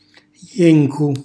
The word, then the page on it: jencu: Calabrese